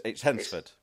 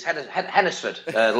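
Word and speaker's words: Hednesford: No, it's like Wednesday. It's Hensford. – It's Hennesford. Locals pronounce it Hennesford. Hennesford.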